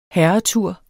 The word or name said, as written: herretur